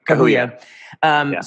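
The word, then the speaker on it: Kahiu: Directed by, how do you say her last name? Wanuri, is it Kahuya? – Kahuya.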